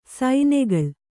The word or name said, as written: ಸೈನೆಗಳ್